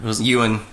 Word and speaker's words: Ewan: Which, he's played by Ian McGregor. – Ewan. – It's Ewan. – Obi-Wan. – It's Ewan McGregor.